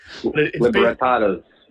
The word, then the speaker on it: Libertadores: Libertadores.